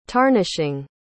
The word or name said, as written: tarnishing